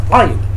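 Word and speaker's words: Ayin: Iron is one of five gutturals, right? And it's pronounced iron. You especially listen to Sephardi, listen to Yemenites. It's a sounded letter.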